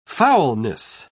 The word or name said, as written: foulness